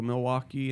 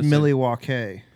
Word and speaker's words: Milwaukee: I don't really want to go to Milwaukee anytime soon. – It's pronounced Milwaukee. – Milwaukee.